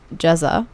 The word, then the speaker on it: Ceza: So next we have a song by a Turkish rapper named Ceza. It's spelled Ceza though, like C-E-Z-A, but in Turkish the C is pronounced as a J.